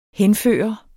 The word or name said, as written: henførende